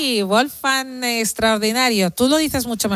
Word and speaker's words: Wolfgang: Bueno, podrías decir Wolfgang y no te diría yo que está mal. – O sea. Wolfgang, vale, perfecto. Pues señores, señores, Cristina lo ha pronunciado bien y por escrito es Wolfgang.